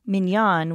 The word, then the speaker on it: minyan: I like to pronounce it minyan, which is how it's pronounced in Hebrew, because then I'm not talking about a little yellow man.